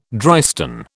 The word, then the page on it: drystone: British English